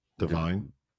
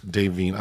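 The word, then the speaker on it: Da'Vine: Am I saying it incorrectly? Divine? – It's not divine. – Devine, I'm so sorry.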